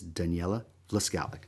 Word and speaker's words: Daniela Vlaskalic: I'm your host, and joining me once again is Daniela Vlaskalic.